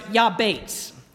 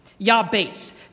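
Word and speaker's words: Yabates (יַעְבֵּץ): He says, the English rendering is Jabez, but the Hebrew is pronounced Yabates. Yabates. The second syllable sounds like the word Bates.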